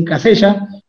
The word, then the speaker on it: Casella: Y ahí fue donde apareció Juan Manuel Casella, se escribe Casella, pero bueno, acá le dicen Casella, eh, en, Uruguay.